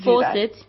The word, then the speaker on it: Fawcett: Please do Fawcett.